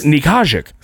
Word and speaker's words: Nickajack: It's spelled with a K-N-I. Nickajack. – It's Nikajik.